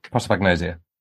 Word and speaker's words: prosopagnosia: Now, I want to say Prosecco disease, but I'm going to try and pronounce it as prosopagnosia. That's the one.